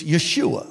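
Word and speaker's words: Yeshua (ישוע): So you could say that Jesus' Hebrew name is Joshua, but the proper pronunciation, as I understand it, is Yeshua.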